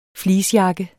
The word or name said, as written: fleecejakke